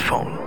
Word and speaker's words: Phaune: It's spelled P-H-A-U-N-E, but it's pronounced phone.